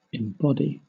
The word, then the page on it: embody: British English